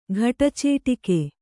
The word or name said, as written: ಘಟಚೇಟಿಕೆ